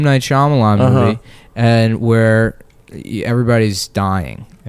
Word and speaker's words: Shyamalan: I don't want to culturally insensitively say his name, so I want to do it right. M. Night Shyamalan. – Shyamalan.